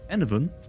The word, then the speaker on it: Enevun: And that you can find my stuff on Twitter and Twitch at Enovan. That's spelled E-N-E-V-U-N.